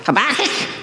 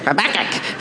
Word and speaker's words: Habakkuk: How do you say that, for me? Habakkuk. Habakkuk. It's like chicken. Habakkuk.